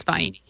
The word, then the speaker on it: Vienna: That's how you say it. You don't. They're not vienna. Yep. It's vienna.